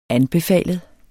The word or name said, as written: anbefalet